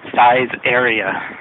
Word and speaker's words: Saizeriya: But the way I remember how to say it is that it sounds a lot like size area. So, size area.